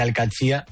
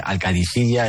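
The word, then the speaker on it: Al-Qadsiah: Bueno, se suele decir Al-Qadsi, pero también hay gente que dice Al-Qadisiyya, es que es difícil, ¿eh? No es fácil, pero Al-Qadsi es lo más fácil.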